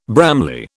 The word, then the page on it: Bramley: British English